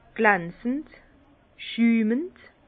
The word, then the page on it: glanzend, schümend: Bas-Rhin; Herrlisheim